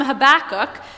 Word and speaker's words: Habakkuk: In the story that we hear this morning from Habakkuk, you pronounced that perfectly, Jason.